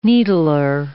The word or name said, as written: needler